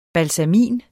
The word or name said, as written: BALSAMIN